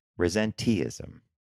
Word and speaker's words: resenteeism: And Shop Talk this week is, I had to pronounce that a couple times, and I think I'm doing it right because it's a double E, resenteeism. – Yes, and I actually went to and found a video where a number of experts were talking about it. And in fact, that is how you say it.